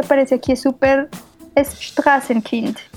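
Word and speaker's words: Straßenkind: Strassenkind.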